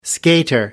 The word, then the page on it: skater: American English